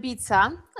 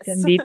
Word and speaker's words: Dębica: Dębica. Yes, Dębica? – exactly.